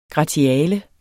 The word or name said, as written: gratiale